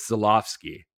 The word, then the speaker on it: Żuławski: And what's funny is until we started talking, the only one I had not done that to was Żuławski. And then I realized, oh yeah, his W's a V.